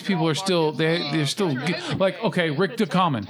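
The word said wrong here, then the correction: Ducommun: I don't know how you pronounce his name. – Dukeman. – There you go.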